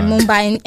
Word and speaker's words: Mumbai: A man gets in Mumbai, I think. Did I say that right? – Yeah, Mumbai, in India.